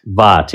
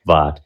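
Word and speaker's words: Vogt: Vaught, yes. It's V-O-G-T. He works with Word on Fire and Bishop Barron, but it's pronounced Vaught.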